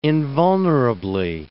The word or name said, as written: invulnerably